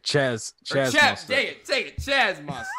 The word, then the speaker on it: Chaz Mostert: The winner of the 2014 Bathurst 1000 was Kaz Mostert. I think that's how you say it. – Chaz Mostert.